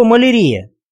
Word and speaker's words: малярия: Отсюда происходит слово «малярия», которое обыкновенно произносится с неправильным ударением на «и».